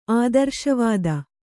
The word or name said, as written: ಆದರ್ಶವಾದಿ